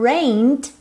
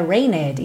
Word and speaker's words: rained: Cuidado para não falar rained out, porque o E do rained não é pronunciado, ok? Então não é rained, e sim rained. Vai do N pro D, rained.